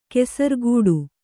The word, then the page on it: ಕೆಸರ್ಗೂಡು: kesargūḍu